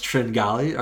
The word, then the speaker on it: Tringale: his name is pronounced Tringali.